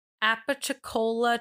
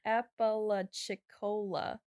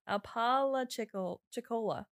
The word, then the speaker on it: Apalachicola: There were two water basins, the Apalachicola Chattahoochee Flint River Basin. – I think you still said it wrong. Apalachicola. – That is literally what I said. – Apalachicola. Coca-Cola.